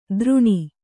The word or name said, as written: ದ್ರುಣಿ